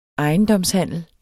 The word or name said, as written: ejendomshandel